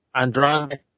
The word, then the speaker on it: Andrade: Okay, so how do you pronounce it? – It's Andrade. – Oh. Andrade.